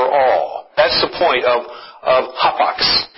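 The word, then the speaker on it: hapax (ἅπαξ): In the Greek, this is a word that is pronounced hapax.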